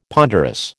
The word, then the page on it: ponderous: American English